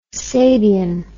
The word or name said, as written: Sabian